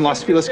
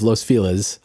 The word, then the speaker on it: Los Feliz: In Los Feliz, California? – Yes. – As a former resident of Los Feliz, a neighborhood in Los Angeles, I thought, well, that's not really a place.